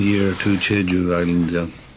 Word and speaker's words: Jeju: Anyway, back to San Francisco and the island of Chehu. Is that how it's pronounced? – Jeju.